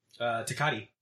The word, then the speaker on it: Tecate: Tecate.